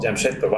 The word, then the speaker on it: Jamshedpur: Jamshedpur, wow.